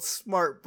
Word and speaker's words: Smartt: I was going to say, Tennessee has a place that's called Smart, but it's spelled S-M-A-R-T-T.